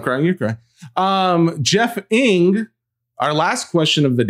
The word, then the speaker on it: Ng: This is my favorite person in the world named Jeff with a G is Jeff Ng, who also instructed us when you see the last name as an Asian last name of NG, it is pronounced Ng.